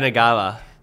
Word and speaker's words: Yanagawa: Yanagawa.